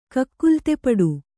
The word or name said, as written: ಕಕ್ಕುಲತೆಪಡು